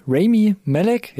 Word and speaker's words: Rami Malek: Und die Hauptrolle spielt Rami Malek, jetzt so ist die Betonung, glaube ich, richtig.